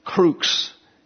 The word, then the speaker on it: crux: The cross, crux is the correct pronunciation. The X and the S are real close on the keypad, and I hit the S, it should have been an X.